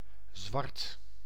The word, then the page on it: black: /ˈblæk/; British English; [blæk]